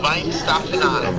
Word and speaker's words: Weihenstephaner: And then so he had his beer, and then I had a Spaten Oktoberfest, and then I had a Korbinian by uh, pronounce the German name for me, Weihenstephaner. Ooh, Weinstephaner. Weinstephaner.